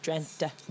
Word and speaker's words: Drenthe: And then a week later, we had the Ronde van Drenthe. Is that the correct pronunciation? – I think it's Drenthe. Like with a soft E in the end, Drenthe.